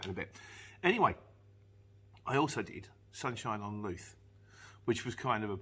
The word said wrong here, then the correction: Louth: He said, for future reference, Luth is pronounced like mouth, so it's actually Sunshine on Louth.